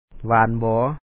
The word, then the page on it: ບໍ່ສູງບໍ່ຕ່ຳ: bɔ̄ɔ sǔuŋ bɔ̄ɔ tām